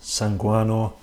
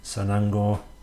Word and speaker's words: sanango: After we'd been sat around talking for a while, we were given the opportunity to ingest what at the time I thought was sanguano, but it's actually pronounced sanango, and the full name is shirik sanango, if I'm pronouncing it right.